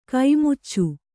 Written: ಖಮಾಚು

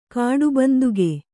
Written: ಕಾಡುಬಂದುಗೆ

